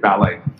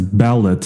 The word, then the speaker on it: Ballet: Oh, so right now I know that it's supposed to be pronounced as ballot, so it sounds more like Bitcoin wallet. – No, it's pronounced ballet. Ballet as in the dance, as in the art form. People dance ballet, the performance art, but not ballot. So it does not rhyme with wallet.